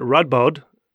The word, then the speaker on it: Radboud: And this is at Radboud University. I don't know if I pronounced that correctly. – Perfect.